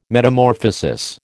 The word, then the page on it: Metamorphosis: American English